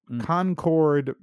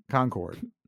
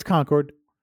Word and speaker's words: Concord: Okay, apparently in Concord, Massachusetts, you may not sell or distribute. – Concord. – Am I saying that wrong? – Yeah, it's Concord. – Right.